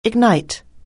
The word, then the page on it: ignite: anglais